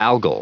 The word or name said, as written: algal